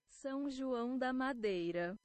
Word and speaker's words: São João da Madeira: Let's see if I can get a Portuguese pronunciation. – São João da Madeira. – San Joao da Madeira. All right, so that is where they're from, at least a better pronunciation at least by way of Google Translate.